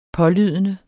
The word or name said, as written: pålydende